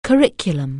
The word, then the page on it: curriculum: anglais